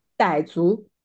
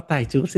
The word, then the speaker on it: 傣族: 怎么读啊？ – 傣族，哦，傣族，谢谢，